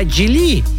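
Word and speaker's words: Geely: Она знает, что правильно говорить не Джили, а Джили. По-моему, так.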